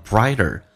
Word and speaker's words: brighter: brighter，这个 T 在美式发音要被浊化，发成 D 这个发音 I 饱满，前重后轻，轻度卷舌 Brighter 更加的美好 Brighter happier， P 要被浊化成 B， happier， happier，前重后轻， richer，短元音 A 加个卷舌音 R， richer tomorrow。